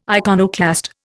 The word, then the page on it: iconoclast: Indian